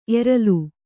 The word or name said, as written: ಎರಲ್